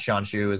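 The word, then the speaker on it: Shawn Xu: So at Sean Xu is my handle on Twitter, and on LinkedIn, you can just search my name and you'll find me.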